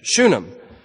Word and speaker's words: Shunem: I don't know why I stumble over that word, but I do. I'm eventually going to get it right. Shunem. It seems like there should be another syllable there somewhere. But anyway, Shunem.